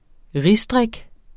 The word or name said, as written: risdrik